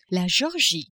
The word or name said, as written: Georgie